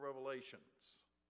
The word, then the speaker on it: Revelation: And after this Sunday, I don't want to hear any of you call it the book of Revelations. It is Revelation. It's amazing how many people mispronounce that.